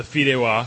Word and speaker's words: fideuá: Now, let's move on here to the fideos. – The fideuá. – Fideuá. I knew I was pronouncing it wrong.